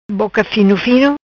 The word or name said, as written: Boca Fino Fino